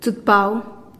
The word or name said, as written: tsutpau